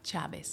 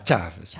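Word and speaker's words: Chávez: In 1998 werden die gewonnen door Hugo Chavez, een man, zeg je dat eigenlijk goed, Chavez? – Chavez. – Ja, maar de klemtoon wel op A, hè? Ja. Chavez.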